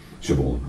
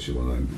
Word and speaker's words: Siobhan: This is Siobhan. Siobhan. I always call it Siobhan, because that's the Scottish way of pronouncing it, Siobhan.